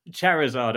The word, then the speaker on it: Charizard: Charizard? – Charizard, a fire flying dragon type Pokemon.